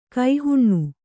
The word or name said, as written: ಕುಹನ